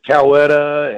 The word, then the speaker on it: Coweta: You know, what's really funny is listening to the pronunciations of Coweta. It's Coweta, and, you know, people just they have trouble pronouncing it even at the airports and stuff.